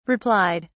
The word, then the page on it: replied: British English